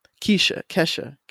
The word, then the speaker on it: Kesha: Even with Ketcha, I hope I'm pronouncing her name correctly. Kesha. I'm sorry. – I think it was Kesha. – Yeah. It's Kesha.